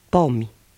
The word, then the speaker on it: pomi: I is almost silent at the end of words, except when preceded by a consonant. – Pomi, cartofi, but metri.